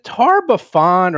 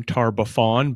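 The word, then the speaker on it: Tar-Baphon: So is it Tarbafan or Tarbafot? I've actually heard it pronounced a couple of different ways. – I myself call him Tarbafan, but for the purposes of a lot of the adventure path, I want to focus on his title of the Whispering Tyrant.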